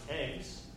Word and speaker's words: EGCS: My compiler was called EGCS, which is pronounced eggs.